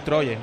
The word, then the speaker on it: Troyes: Yo he dicho del Troyes, ¿no? He dicho, ¿no? – Sí, el Troyes está muy bien dicho, Rubén.